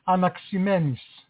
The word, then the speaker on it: Anaximenes: I don't know if I said it correctly. – Anaximenes. – Oh, thank you.